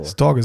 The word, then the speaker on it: Stage: Storge, so spricht man es aus. Guck mal, da kommt der, Nord, der Nordische durch.